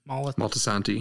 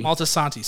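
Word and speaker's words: Moltisanti: Molitani. Is that the right way to pronounce that? – Maltasanti. – Maltasanti, sorry. Mm-hmm.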